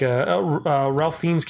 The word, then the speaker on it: Fiennes: But I did like Ralph Fiennes' character. How do you pronounce his name? Fiennes? – Ralph Fiennes. – Fiennes? – Fiennes.